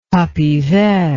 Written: tapis vert

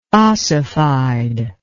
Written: ossified

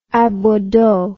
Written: aboideau

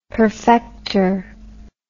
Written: perfecter

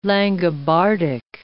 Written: Langobardic

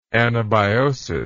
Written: anabiotic

